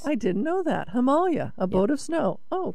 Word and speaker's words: Himalaya: That word is pronounced a lot of people say Himalayas, but Himalayas, is that Himalaya? Is that the correct pronunciation? – Yes. It's Hima means snow. Alaya means a boat, so you say Himalaya, a boat of snow.